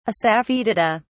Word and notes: assafoetida: British English

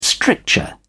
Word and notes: stricture: British English

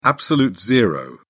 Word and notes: absolute zero: British English

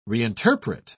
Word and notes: reinterpret: British English

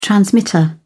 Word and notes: transmitter: British English

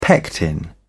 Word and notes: pectin: British English

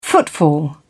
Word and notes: footfall: British English

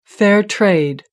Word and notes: fair trade: American English

